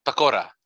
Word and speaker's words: pakora: And then they had some pakota. Is that how you say it? – Pakora.